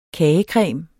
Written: kagecreme

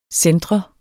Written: centre

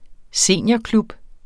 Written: seniorklub

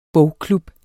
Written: bogklub

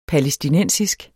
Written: palæstinensisk

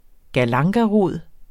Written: galangarod

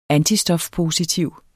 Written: antistofpositiv

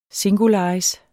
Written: singularis

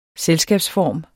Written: selskabsform

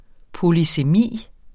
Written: polysemi

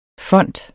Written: font

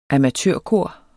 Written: amatørkor

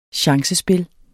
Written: chancespil

